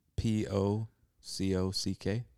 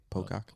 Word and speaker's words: Pocock: P O C O C K Pocock. Oh, Pocock. Okay.